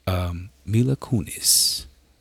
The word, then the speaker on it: Kunis: Mila Kunis.